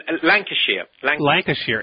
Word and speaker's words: Lancashire: Is it Lancashire? – Yeah, there is a body. – Is that how you pronounce it? – Lancashire.